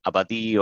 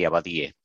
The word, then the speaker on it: Abadie: It's a French name. I am originally from Spain, and I live in the United States. So at least there are like three correct ways to pronounce it in three different languages. But people say usually Abadie or Abadi, and in Spanish, like originally, we say Abadie.